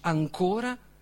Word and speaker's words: ancora: Ancora e ancora in italiano sono la stessa parola. Slitta solo l'accento.